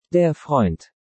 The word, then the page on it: Der Freund: Deutsch